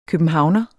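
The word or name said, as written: Københavner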